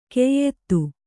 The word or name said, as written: ಕೆಯ್ದು